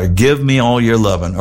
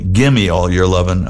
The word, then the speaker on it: Gimme All Your Lovin': And finishing things up in the set and the show, give me all your lovin', or I should say it properly, gimme all your lovin'.